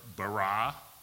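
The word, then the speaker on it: ba-ra (בָּרָא): So spell bara or ex nihilo, bara, B-A-R-A. That one I can spell. Now it's actually pronounced more ba-ra, B-A-W-R-A-H would be the correct pronunciation, but it's easier for me to say bara.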